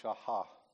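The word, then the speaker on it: shachah: I think you kind of pronounce this without the C. It's shahah.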